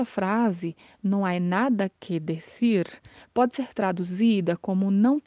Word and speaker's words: decir: Decir. Decir é o verbo dizer em espanhol. Atenção à pronúncia, porque o C tem aquele som com a língua entre os dentes, decir.